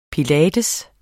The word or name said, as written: pilates